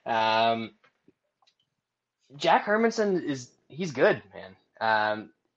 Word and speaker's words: Hermansson: Jack Hermansson. Hermansson is Airman. The correct pronunciation.